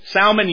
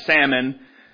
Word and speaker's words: Salmon: Now, Salmon, you pronounce the L in his name, not like the fish where you remain silent. Salmon. Salmon.